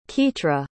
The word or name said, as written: Kitra